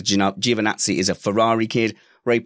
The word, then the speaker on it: Giovinazzi: Ian Caldwell says it's pronounced Giovinazzi.